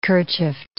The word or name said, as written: kerchiefed